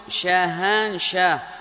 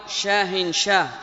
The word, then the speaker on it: شاهان شاه: ثم قال سفيان وابن عيينة: من رواة هذا الحديث قال مثل شاهان شاه، شاهان شاه، وتنطق أيضا شاهن شاه بالتنوين، وهي كلمة أعجمية، كلمة أعجمية، وكان تردد في زمن سفيان رحمه الله تعالى.